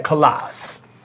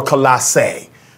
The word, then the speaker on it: Colossae: Because remember, we talked about this last week, that it is believed Philemon was a member of the church at Colossae, or Colossae, depending upon which way you choose to pronounce it.